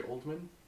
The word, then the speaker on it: Oldman: Gary Oldham. – Isn't it Gary Oldman? – Is it Oldham or Oldham? – It's spelled like old man.